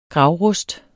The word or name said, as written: gravrust